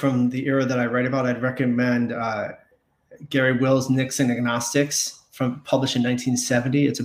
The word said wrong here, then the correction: Agonistes: I always think of it in terms of agnosticism because Nixon was such a polarizing figure in his day. But that's not correct.